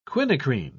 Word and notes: quinacrine: British English

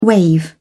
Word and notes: waive: British English

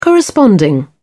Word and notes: corresponding: British English

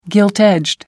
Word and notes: gilt-edged: American English

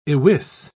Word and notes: iwis: British English

